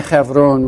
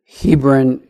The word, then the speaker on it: Hebron: When the beloved wife of Abraham, Sarah, dies, down in the Hebron region, many of us in our English tradition call it Hebron. Hebron is actually pronounced Hebron.